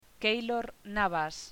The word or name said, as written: Keylor Navas